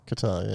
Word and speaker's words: Qatar: Qatar were the first... how do you say that country? – Qatar, yeah.